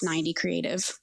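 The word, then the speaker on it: 9D Creative: So it's the number nine, letter D, creative, is the roots of that. So it's pronounced 90 creative.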